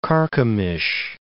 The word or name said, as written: Carchemish